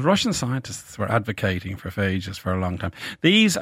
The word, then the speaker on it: phages: We talked very early on in our series about, are they pronounced phages? – Phages, yeah, absolutely. – P-H-A-G-E-S. – Yes, that's right.